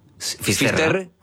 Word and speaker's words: Finisterre: Entonces, llegó a Finisterre. ¿Se dice así? – Finisterre.